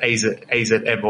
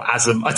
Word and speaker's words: AZM: the name is written, it's stylized as AZM. – Okay. – And I think Mercedes pronounced this as, I don't know how she pronounced it, AZM or Azum. I don't know what she said, but it's pronounced Azumi.